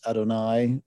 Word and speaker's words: Adonai: and how it'd be pronounced in Jewish tradition and reading the Hebrew text as Adonai or as Lord, as it's often translated in the Greek.